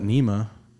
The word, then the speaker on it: NMEA: I guess first question that I have that somebody else had is, why do we call it NEMA when it's spelled N-M-E-A?